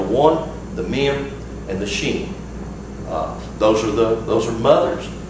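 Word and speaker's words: shin: And this is the sheen, and it can be pronounced sheen or seen.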